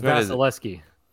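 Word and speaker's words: Vasilevskiy: First it's a V, not an E. It's Vasilevsky. Andre Vasilevsky. – Can we check that pronunciation, please? – That is a correct pronunciation.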